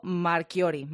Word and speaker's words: Marchiori: Oye, lo he dicho bien, Marchiori es el apellido. – Lo has dicho perfecto.